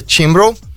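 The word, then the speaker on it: Cianbro: E ele contratou a companhia chamada Ciambro, que a gente pronuncia Timbro,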